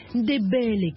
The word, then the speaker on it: Ndébélé: Ndébélé, comme elle le prononce.